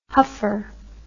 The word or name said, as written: huffer